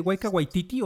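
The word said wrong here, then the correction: Taika Waititi: Taiki Waititi, el director divertidísimo.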